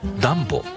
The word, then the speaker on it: DUMBO: ニューヨークを知っている方でもダンボという名前を聞いたとき少し首をかしげることがあります。 DUMBO と書いてダンボと読みます。